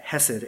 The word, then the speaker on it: hesed: You say it like that, hesed, H-E-S-E-D, right? You can get a little more technical in the way you pronounce it, but I don't want to scratch the back of my throat today by saying it properly, hesed, right?